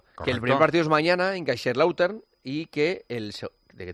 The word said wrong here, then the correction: Kaiserslautern: Partido es un poco Melchor, como lo has pronunciado. – Es que... – ¿Cómo es? – Kaiserslautern.